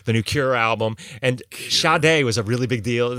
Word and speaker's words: Sade: Well, they had the dividers for the albums, and on the one for Sade, underneath it, you know, in parentheses, it said pronounced Sade.